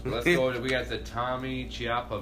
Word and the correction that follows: Ciampa: versus Johnny Gargano versus... – Butcher their names. – What? – Well, Johnny Gargano and Tommaso Ciampa.